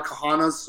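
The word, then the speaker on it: Cahana: Thank you that there's a lot of Kahanas, so just spell it with a C, C-A-H-A-N-A, Alex Kahana on LinkedIn.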